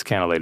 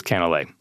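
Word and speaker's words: canelé: And by the way, people mispronounce it all the time. They say canel, which is cinnamon in French. So it's a very similar spelling, but it is Canelet.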